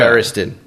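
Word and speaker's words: Feresten: I usually say Ferris like wheel, 10 like the number. – Well, yeah. – Ferriston.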